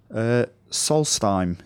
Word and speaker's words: Solstheim: So the first one that we got is from Femring42 on Twitter, and he says, Solstheim, is that how you say it? – Yeah.